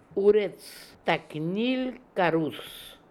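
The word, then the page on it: Ooretstaknilkarool: Russian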